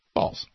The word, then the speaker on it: Baals: B-A-A-L-S, pronounced Balls. – Balls.